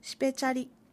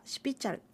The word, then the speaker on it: シピチャル: シペチャリという言い方もあるようですが、録音資料に残されているアイヌ語話者の発音ではシピチャルと発音されることが多いようです。